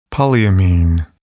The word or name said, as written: polyamine